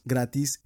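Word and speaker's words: grattis: Gratis en sueco, así como lo pronuncié, es felicidades.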